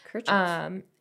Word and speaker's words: Kirchhoff: Kirchhoff, I think is how you pronounce his name. – Kirchhoff.